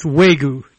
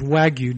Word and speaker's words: Wagyu: So for 25 years or so, I have thought that the famous livestock behind Japanese Kobe beef was actually pronounced Wagyu, W-A-Y-G-U, and I discover now, because it's in large letters on the brochure from the gentleman we're going to be talking to next, that in fact it's Wagyu, W-A-G-Y-U.